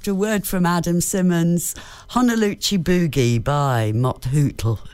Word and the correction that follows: Mott the Hoople: Did I say that right? Mott the Hoople.